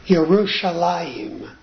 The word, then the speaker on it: Yerushalayim: Yerushalayim starts with a Y, not a J.